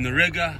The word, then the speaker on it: Riga: So, I'm out here in Riga, which rhymes with nigga.